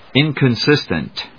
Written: inconsistent

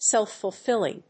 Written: self‐fulfilling

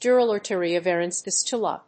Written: dural arteriovenous fistula

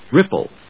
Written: ripple

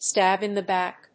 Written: stab in the back

